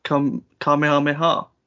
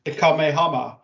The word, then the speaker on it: Kamehameha: I thought you were gonna say it wrong then. – I was initially. I was gonna say it like I've seen some streamers that have said it. I've been a bit. – The Kamehama. – The Kamama Mama.